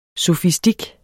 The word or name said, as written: sofistik